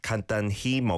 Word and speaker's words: 간단히: And yeah, it is a he and then an E vowel sound there, 간단히, but often pronounced just 간단히, 간단히 먹어, like that, not over-pronouncing the he sound.